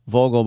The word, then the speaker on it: Vogel: Vogel, by the way, means bird in German, and you always pronounce a V like an F, so Vogel.